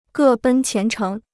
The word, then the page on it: 各奔前程: gè bèn qián chéng